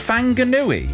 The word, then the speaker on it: Whanganui: Whanganui, the WH is pronounced like F by the locals. Whanganui.